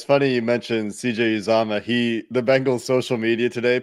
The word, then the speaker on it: Uzomah: Azoma is going crazy. I always pronounce his name wrong.